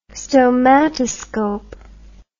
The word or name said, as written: stomatoscope